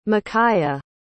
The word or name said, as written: makaya